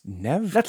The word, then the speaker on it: Neve: Is it Neve Campbell or Neve Campbell? – It's Neve. – That's what I thought.